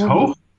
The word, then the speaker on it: Hoog: Yes, almost. It's Hoog. But everyone has problems with the G at the end. – Yeah, it's the Dutch G. It's always complicated for non-Dutch speakers to pronounce it.